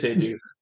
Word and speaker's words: Eduth: It is Eduth. I looked it up earlier. I was making sure that I didn't say it wrong. But Eduth. – It looks like it says, I do it.